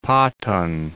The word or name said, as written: Patan